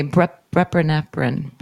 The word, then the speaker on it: buprenorphine: How do you say buprenorphine? Buprenorphine. – Let me see. – Buprenorphine. – Yeah, buprenorphine.